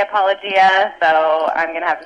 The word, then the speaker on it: apologia: And, of course, your father is always ribbing or insulting Pastor Jeff about mispronouncing apologia. He says right. – It's apologia.